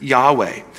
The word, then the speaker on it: Yahweh: The exact pronunciation of YHWH is uncertain. We usually pronounce it Yahweh, because the Jewish people consider the personal name of God to be so holy that it should never be spoken aloud.